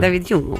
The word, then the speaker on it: David Hume: David, come si chiama lui? David Hume, come lo pronunciamo? – David Hume. – David Hume, ok.